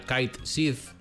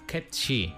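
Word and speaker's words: Cait Sìth: Isto, escrito, lido con fonética galega, sería Kite Sith, é un nome que en gaélico escocés significa gato fada. Que pasa? Que é que se pronunciaría algo parecido a Ketchi. Pero resulta que na dobraxe do remake menciónano como se fose lido en inglés, Kate Sith, aínda que a personaxe ten acento escocés.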